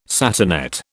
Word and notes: satinette: British English

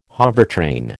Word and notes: hovertrain: American English

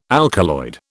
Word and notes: alkaloid: British English

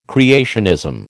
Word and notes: creationism: American English

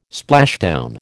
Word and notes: splashdown: American English

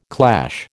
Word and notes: clash: American English

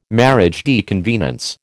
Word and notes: mariage de convenance: American English

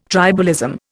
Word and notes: tribalism: Indian

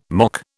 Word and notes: mock: British English

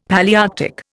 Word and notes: Palaearctic: Indian

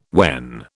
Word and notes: when: British English

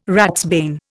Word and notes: ratsbane: Indian

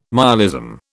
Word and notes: myalism: British English

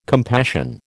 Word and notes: compassion: American English

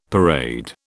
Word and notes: parade: British English

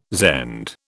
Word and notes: Zend: British English

